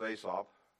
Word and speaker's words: Asaph: It is a psalm of Asaph. Or Asap, depending on how you want to pronounce that.